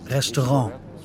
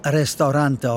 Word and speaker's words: Restaurant: Er sagte immer Restaurant, französisch ausgesprochen, nicht Restaurante auf Portugiesisch.